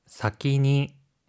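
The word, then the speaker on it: 사키니 (先に): 먼저 사키니. 사키니. – 사키니라고 하는 표현이에요. 앞선이라고 하는 한자는요. 뜻으로 읽을 때 사키라고 읽고요.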